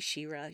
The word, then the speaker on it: Shira: It's spelled S-H-I-R-A, but even so, the way that it's pronounced, I'm just like, Shira.